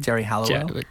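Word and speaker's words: Geri Halliwell: How did you say her name? – Jerry Halliwell.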